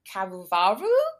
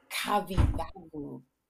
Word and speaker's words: Kavyavu: And we are lucky to have John Cavavaru. Did I pronounce that correct, John? – Almost. – Ah. John Kavyavu. – Kavyavu.